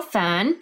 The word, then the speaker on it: Feng: Joining me today is Rachel Feng.